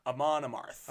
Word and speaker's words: Amon Amarth: Amon Amarth.